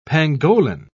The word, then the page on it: pangolin: British English